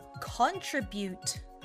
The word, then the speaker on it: contribute: Contribute. contribute. Sounds just fine. TRI のところは鳥よりもチュリというふうに発音すると言いやすくなるかもしれません。